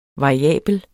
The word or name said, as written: variabel